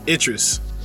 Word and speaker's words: Idris: I, is it Idris or El, Idris? How do you say his uh, name? – Idris. – Idris. Okay, Idris Elba.